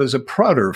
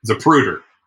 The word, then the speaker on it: Zapruder: Since your very first episode, I've given your shows all the lackadaisical attention of a Zapruder film. – Zapruder. – Okay, you say Zapruder, I say Zapruder, mainly because I said it wrong. But actually, you're right, it's a Zapruder film.